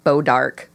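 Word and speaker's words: Bois d'Arc: We're from a state that has a city called Bodark, but it's spelled. – Yeah. – Like, in the French way, like B-O-I-S, new word.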